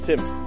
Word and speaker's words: Tim: T-H-E-O. – My name is Tim. – Tim, can you spell that for me? – T-I-M.